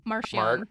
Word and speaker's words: Marchion Ro: That said, I do feel like we have to address the helmet-wearing, eye-owning elephant in the room, which is Marishan Ro, or how do you say his name? Marchion? – Marchion.